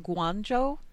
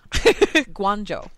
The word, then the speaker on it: Guangzhou: 6606 in the chat channel says pronounce it Guangzhou, apparently, because they go to hospital there. So that's good. Guangzhou.